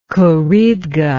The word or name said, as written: Khouribga